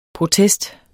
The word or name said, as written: protest